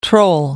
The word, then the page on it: troll: American English